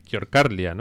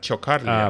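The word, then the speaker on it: Ciocărlia: Por lo que les he oído a ellos pronunciar es como más Fanfara Chocarlia. – Ah, vale, sí, claro, porque la C esa... – La C es como una Ch.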